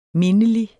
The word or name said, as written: mindelig